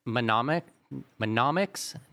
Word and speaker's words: mnemonics: So, okay, so the things that you've been describing so far, is this mnemonics? How do you say this? – Mnemonics. – Yeah. I have it written down here. It starts with an M.